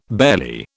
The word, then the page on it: barely: British English